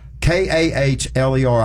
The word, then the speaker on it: Kahler: And in the 305 wing sprint cars, the Rick's Crane Service 305 sprint cars, Holly Springs, Mississippi, Braxton Kaler, Kahler. I hope I'm spelling it right. I mean, I'm pronouncing it right. What do y'all think? – I think that's right.